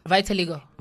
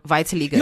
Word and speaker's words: vitiligo: Vitiligo. – Vitiligo. – No, there's so many. – Oh, yeah, it's the way I it's say vitiligo, easy. Some say vitiligo.